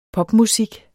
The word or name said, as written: popmusik